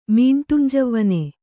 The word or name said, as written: ಮೀಂಟುಂಜವ್ವನ